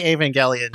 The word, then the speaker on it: Evangelion: And I mean, like so much of like like the way Evangelion. I always forget if it's the hard G or the soft G. – It's hard G.